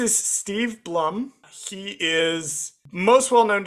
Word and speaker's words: Blum: Uh, it, it's Steve Bloom. Steve Bloom is voice. Steve Blum is voicing. I think it's Blum is how you pronounce his last name.